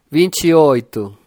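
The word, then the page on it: 28: Brazilian Portuguese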